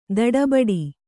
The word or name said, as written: ದಡಬಡ